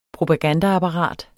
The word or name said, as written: propagandaapparat